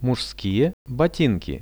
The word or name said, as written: muzhskie botinki